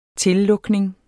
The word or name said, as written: tillukning